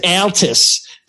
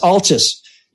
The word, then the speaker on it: Altus: I am happy to pronounce it any way people like, but I would just suggest that when you're a Michigan lager, it's probably pronounced Altus. But yes, Altus.